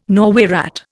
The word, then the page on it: Norway rat: Indian